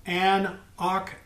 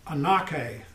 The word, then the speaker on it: anoche: Bearing with is the word anekomai, and it's related to the noun called anoke, and that's spelled, that's not in your handout, that's the noun, and it's spelled A-N-O-C-H-E. It looks like a nochi, but it's anoke, anoke.